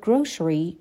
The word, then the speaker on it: grocery: Can do your grocery shopping. Grocery or grocery. این کلمه دو تا تلفظ داره.